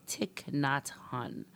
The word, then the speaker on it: Thich Nhat Hanh: So, Thich Nhat Hanh.